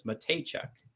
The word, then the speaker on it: Matejchuk: And then Jonathan Williams says Matejchuk is how you pronounce that.